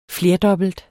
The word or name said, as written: flerdobbelt